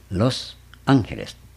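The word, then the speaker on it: Los Ángeles: ロスアンヘレス、アンヘレスはアンジェルですね。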